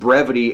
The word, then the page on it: brevity: American English